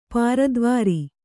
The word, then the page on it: ಪುರದ್ವಾರ: Kannada